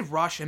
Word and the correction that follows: Roosh: I meant Roosh. That I should have said. I apologize if I mispronounced. It's actually Roosh. I thought it was Rush. It's Roosh. Um, it's not, it's not the fucking band from years ago.